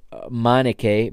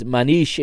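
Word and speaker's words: Manniche: New signings, Claudio Adão and Michael Manish, or Manik, Michael, I believe the way it's the English commentators pronounce it is Michael Manike, but it is Manish in Portuguese, or Manik to some.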